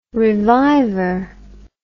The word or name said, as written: reviver